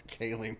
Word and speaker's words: Ghislaine: It's spelled Ghisla. – Yeah, I have no idea. – Oh yeah, you had no idea about the meme. Yeah. No, it's. – What side chick? – Assistant Epstein's side chick. Did you fucking... – And there it is. – There she is. – Yeah, Ghislaine. – Ghislaine Maxwell. – You don't pronounce the S.